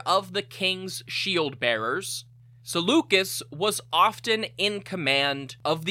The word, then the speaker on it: Seleucus: Finally, the commander of the Hypaspists, Seleucus, variously pronounced as Seleucus, Seleucus, however you want to say it, I'm going to say Seleucus was married to Apama, the daughter of the Sogdian warlord Spitamenes.